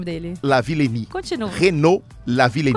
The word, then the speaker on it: Renaud Lavillenie: se a gente está falando de atletas franceses, não dá para perder a chance de citar o Renaud Lavillenie, conhecido aqui como francês do salto com vara.